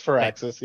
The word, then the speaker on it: Firaxis: how do you pronounce her name? – Firaxis. It's Firaxis.